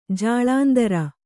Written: ಜಾಲಂದ್ರ

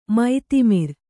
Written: ಮತಿಮರೆ

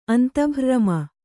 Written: ಅಂತಭ್ರ್ರಮ

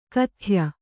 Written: ಖಾದ್ಯ

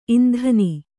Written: ಇಂಧನಿ

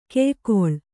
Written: ಕಾಯಗೊಳ್

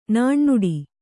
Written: ನುಣ್ಣುಡಿ

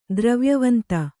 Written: ದ್ರವ್ಯವಂತ

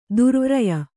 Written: ದುರ್ವೀರ್ಯ

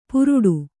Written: ಪುರುಡು

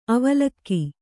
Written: ಅವಲಕ್ಕಿ

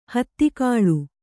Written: ಹಿತಗೊಳ್

